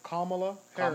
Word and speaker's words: Kamala Harris: To Kamala Harris.